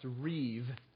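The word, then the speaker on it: reeve (ריב): It's the Hebrew word reb. You could spell it R-I-B, like rib, except it's pronounced reeve.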